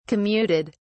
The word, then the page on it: commuted: British English